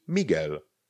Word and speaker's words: Miguel: M-I-G-U-E-L. Víme, že G-U-E vynecháme U ve výslovnosti a zůstane nám tam G. Ale není to G, to by tam to U nesmělo být.